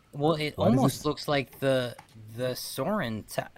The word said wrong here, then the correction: Sauron: That's not his name. How do you say the guy's name? – Sauron.